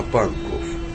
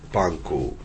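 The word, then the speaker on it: Pankow: Только что отошел поезд в противоположном направлении, на Панков. Как и другие слова с окончанием на «ов», берлинцы, правда, произносят «оу», «панкоу».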